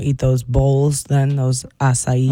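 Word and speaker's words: açaí: I looked at the pronunciation of ASEI. So this is, even the pronunciation doesn't help me. I'm going to play it to see if this helps. – Acai. – That sounds right.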